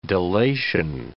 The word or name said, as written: delation